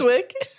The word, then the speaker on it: Chiswick: The area, everything, every Chiswick. – Source. – Chiswick? – What? Is that not how you say it? It's spelled C-H-I-S-W-I-C-K.